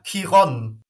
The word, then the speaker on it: Chiron: Chiron is the right pronunciation in Germany. You could also say Chiron or Chiron. There are three possible pronunciations for the CH. It's or K.